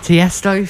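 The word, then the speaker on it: Tiësto: Tiesto featuring Carlo G.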